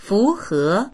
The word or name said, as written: fu2he2